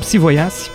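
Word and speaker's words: Psí vojáci: O que a gente vai ouvir hoje é uma banda de rock tcheca chamada Psi Vojace. É com J, mas perguntei pro meu amigo tcheco, ele disse que pronuncia-se como I. Então, Psi Vojace.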